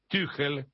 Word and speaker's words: Tuchel: Con Tuchel, Tuchel, así se pronuncia, andaba bastante más sólido.